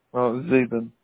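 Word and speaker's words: Zaben: Zabin.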